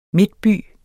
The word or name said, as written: midtby